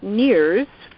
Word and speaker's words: NIRS: And for the rest of the evening, we probably will use our acronym, which is pronounced NEARS for the organizational name.